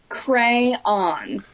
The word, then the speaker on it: crayon: Crayon. That's a crayon. – Crayon. Okay. – Yes, it is not a crown.